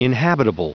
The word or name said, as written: inhabitable